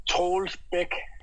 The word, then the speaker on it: Troels Bech: Troels Bech. – Yeah, I've got no chance of that, have I? – Can I call him Troels? – I want to call him Troels Bech.